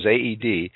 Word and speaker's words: Aoede: And incidentally, when she says AOEDE, that is spelled A-O-E-D-E. So if you're going to search for her on the web, it's A-O-E-D-E.